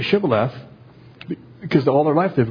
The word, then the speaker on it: Shibboleth: "Then said they unto him, 'Say now Shibboleth.' And he said, 'Sibboleth,' for he could not frame to pronounce it right.